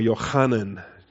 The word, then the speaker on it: Yohanan: And then from verse 7 to verse 9, recognizing the grace that had been given to me, Jacob and Cephas and Johannes, or Yohanan, give it the Hebrew pronunciation there.